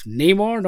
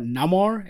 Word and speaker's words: Namor: We got to see Namor, and also it's Namor, not Namor, as I've heard some people pronounce it.